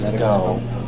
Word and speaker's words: Schou: And SCO is spelled S-C-H-O-U, but fortunately their website explains how it's pronounced, and it's pronounced SCO.